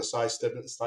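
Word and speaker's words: Si Stebbins: The Sy Stibbons, am I saying that right? – No, yeah, you're saying it right.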